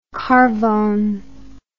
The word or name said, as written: carvone